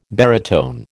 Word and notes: baritone: American English

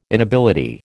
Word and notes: inability: American English

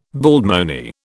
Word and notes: baldmoney: British English

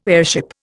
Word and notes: airship: Indian

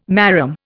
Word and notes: marram: Indian

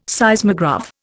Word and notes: Seismograph: Indian

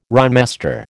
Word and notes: rhymester: American English

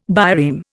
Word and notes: bireme: Indian

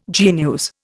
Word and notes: genus: Indian

